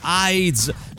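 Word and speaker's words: Haiz: Aiz, si scrive H-A-I, staccato Z, Un uomo felice, edito da del vecchio editore.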